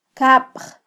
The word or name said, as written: kabj